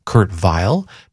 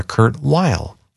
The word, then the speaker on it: Weill: When he came to America, he said that he did not want his name pronounced Kurt Weill, but rather Kurt Weill, and he spoke only English.